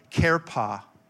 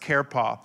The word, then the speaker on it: cherpah: The second word, another version of the word, it's used 73 times, is the word kerpa. C-H-E-R-P-A-H is pronounced kerpa.